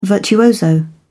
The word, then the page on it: virtuoso: British English